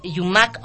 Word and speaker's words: Yumac: Mi Facebook personal, donde ustedes también nos pueden mirar y escuchar directamente, es Yumac Ortiz. Mi nombre se escribe Y-U-M-A-C Ortiz, directamente, todo con mayúscula, y estás conectado a mi Facebook.